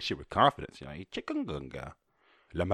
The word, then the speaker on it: chikungunya: How do I say chikungunya pronunciation? There we go, Google. You know what I want. Oh, here we go. – Chikungunya. – Oh, I wasn't that far off.